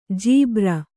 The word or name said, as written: ಜುಬ್ಬರ